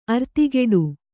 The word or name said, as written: ಅರ್ತಿಗೆಡು